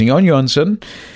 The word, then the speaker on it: Jón Jónsson: It looks like John Johnson. But of course, in Iceland, they pronounce that J as almost a silent Y. So it's Jón Jónsson.